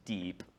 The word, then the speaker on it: DIEP: And then I want to talk about the method that's kind of become more popular lately, and that is it's pronounced DEEP, but the initials are DIEP, which is deep inferior epigastric perforator flap.